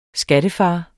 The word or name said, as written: skattefar